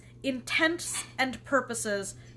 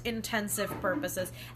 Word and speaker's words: intents and purposes: But you're probably still gonna say it wrong, and that is intents and purposes. Versus intensive purposes.